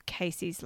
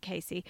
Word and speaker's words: Casie: And poor old Casey, it's spelled C-A-S-I-E, but I think she pronounces it Casey.